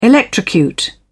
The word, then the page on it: electrocute: British English